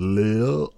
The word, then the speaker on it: Lade: I'm going to say it how they say it. It's Earls of L-A-D-E, Earls of Lille.